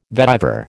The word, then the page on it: vetiver: American English